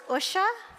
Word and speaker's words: Usha: So one secret about the title is Usha and the Big Digger. So Usha is pronounced Ush, like push, rather than Usha or Usha.